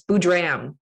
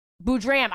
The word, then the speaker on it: Boodram: It's an am rather than an um, but it's very close. Boodram. – Boodram, I just put the emphasis on the wrong syllable.